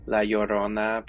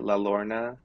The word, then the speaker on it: La Llorona: La Llorona, people always calling it La Lorna. And I'm just like, yo, the double L is a Y.